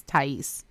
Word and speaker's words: Thais: I think that's how you say that name because I knew someone whose name was spelt like that, and that's how she pronounced her name. So that's why I'm saying it's Thais.